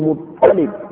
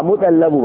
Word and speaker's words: Muttalib: ko Muttalib ake cewa ba Muttalabu ba ba Muttalabu ba a Muttalib